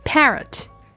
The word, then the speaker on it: parrot: 那小朋友们注意到呢，这个 a 的字母呢，发的是 a a 大口型的梅花音 parrot。